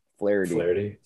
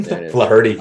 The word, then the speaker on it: Flaherty: I don't know how the inflection of his last name is pronounced. Jack Flaherty. – Flaherty. – There it is. See, Flaherty. – Oh, yeah. Flaherty.